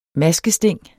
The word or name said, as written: maskesting